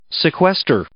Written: sequester

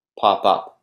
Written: pop up

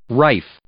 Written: rife

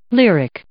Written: lyric

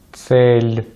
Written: цель

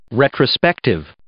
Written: retrospective

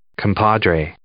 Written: compadre

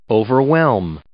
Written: overwhelm

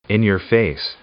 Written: in–your–face

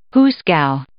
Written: hoosegow

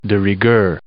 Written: de rigueur

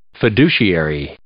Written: fiduciary

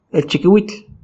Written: elchikiuitl

